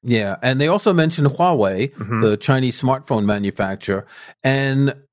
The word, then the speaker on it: Huawei: But again, I've studied Chinese, and I know that it's pronounced Huawei, which might be a little bit difficult if you haven't studied Chinese and don't have the pronunciation. Ars Technica says Huawei is close enough for Western markets, according to the company.